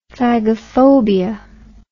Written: phagophobia

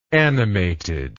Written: animatedly

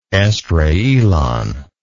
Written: Esdraelon

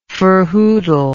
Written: ferhoodle

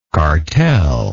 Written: cartelism

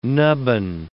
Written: nubbin

